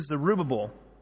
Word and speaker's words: Zerubbabel: So he said to me, "This is the word of the Lord to Zerubbabel." That's how you say it properly. That's the American way of saying it properly.